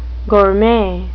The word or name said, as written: gourmet